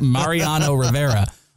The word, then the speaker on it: Mariano Rivera: Why can't I say his name? – Marinara Rivera. Right. – Marinara pasta. Mariano Rivera.